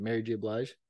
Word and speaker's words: Blige: Mary J. Blige? – Yeah.